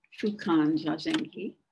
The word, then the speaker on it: Fukanzazengi: The title, Fukan Zazengi, page skipped a little too far. We can break the title down this way. The Fu character, pronounced Fu, means universal or always applicable.